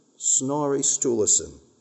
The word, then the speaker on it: Snorri Sturluson: Snorri Sturluson. S-N-O-R-R-I, great name for a child.